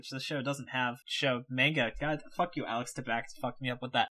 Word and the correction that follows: Alex Trebek: You didn't even say his name, Alex Tabak. Alex Trebek.